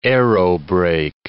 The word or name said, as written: aerobrake